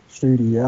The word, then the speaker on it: Studi: No, that's correct. Yeah, Patrick Studi, I would say. It's a Swiss name. It's hard to pronounce in English.